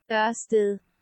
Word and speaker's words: Ørsted: And in Danish, it's pronounced as Ørsted.